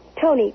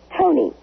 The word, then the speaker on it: Toni: Yes, Tony, with an I. – What? – Tony, it's spelled T-O-N-I, not with a Y.